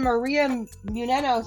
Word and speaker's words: Maria Menounos: And oh, and Maria Menounos. Thank you for saying it right.